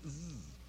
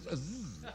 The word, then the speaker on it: Sze: Joe, what is the fellow's name? And he says, Zzz. I said, what? He says, Zzz. I says, how do you spell it? He says, S-Z-E.